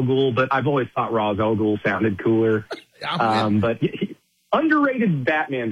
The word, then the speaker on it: Ra's al Ghul: But Rajah Gul, and that's the other thing. In the movie, they call him Rajah Gul. In the comic book, it's pronounced Ra's al Ghul, and like Batman fanboys will jump on you.